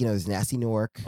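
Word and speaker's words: Gnasty Gnorc: Did you pronounce the G in the first one? Nasty? – No. Nasty Nork. – Oh, okay, yeah. I thought you still had the G in there, like Nasty. – Nasty.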